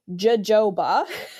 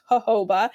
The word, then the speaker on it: jojoba: And jojoba oil is another really great option. The word looks like jojoba to most people, but it's pronounced jojoba.